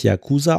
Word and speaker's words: Yakuza: Also, ta ta tatsächlich, ähm, also Yakuza wird im Japanischen tatsächlich Yakuza ausgesprochen. Ah, und ah. und ähm, tatsächlich ist die Betonung neutral. Also hier heißt es wörtlich: No stress on any part in particular. A tiny bit more on the ya, if you will, but in general flat.